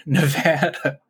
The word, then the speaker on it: Nevada: That's how they say it here, Nevada.